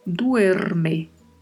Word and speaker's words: duerme: E atenção para não esquecer de pronunciar esta primeira letra E que tem aí. Temos que falar mesmo duerme.